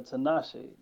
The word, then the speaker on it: Tinashe: Ooh, Tinashe. How do you say that shit? – Yeah, Tinashe.